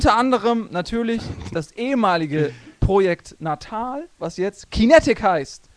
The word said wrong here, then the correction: Kinect: Falsch, nee. du hast das schon falsch nee. gesagt. – No. Das habe ich falsch gesagt. Kintech. Kintech. – Kinect. Kinect, verdammt. Kinect.